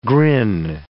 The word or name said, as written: grin